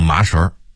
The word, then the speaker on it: 麻绳: 麻绳，绳子的绳，正确读音当然是绳，但北京人说麻绳。